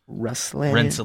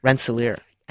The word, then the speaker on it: Rensselaer: And I'm still going to have problems with this name, Rensselaer. – Rensselaer.